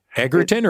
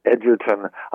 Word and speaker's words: Egerton: So is it Edge or Egg? Egerton or Edgerton? – It's Edgerton.